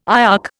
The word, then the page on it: IOC: Indian